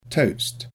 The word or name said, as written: toast